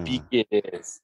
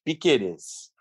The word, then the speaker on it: Piquerez: Eu até falei pro Thiagão, eu ia mandar uma mensagem pro Thiagão, que agora eu descobri como se pronuncia Piquerez. É realmente Piquerez.